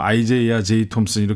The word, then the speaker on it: 아이제이아 제이 톰슨: 사실 이 아이제이아라는 이름이 그 이전에는 우리가 보통 표기할 때 이사야라는 이름으로 성서에 나오는 같은 이름입니다. – 네. – 그런데 이제 발음상 이게 표기법이 국립국어원에서도 현재 아이제이아로 표기가 이제 확정이 되어 있고요. 그래서 네. 좀 약간 낯설지만 뭐 아이제이아 제이 톰슨 이렇게 부르게 됩니다.